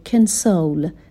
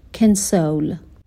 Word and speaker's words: console: دقت کنید که کلمه کانسول به معنای کنسول هست، ولی همین کلمه با تلفظ متفاوت تبدیل به یک فعل میشه به معنای تسلیت گفتن، دلداری دادن و تلفظش به این شکل میشه کنسول. صدای اولش دیگه صدای آ نیستش، کنسول.